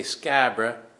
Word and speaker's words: Scarborough: And they say Scabra, that's how they pronounce it.